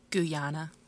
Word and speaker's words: Guyana: Guyana.